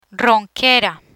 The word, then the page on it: ronquera: spanish